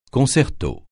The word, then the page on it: concerto: francais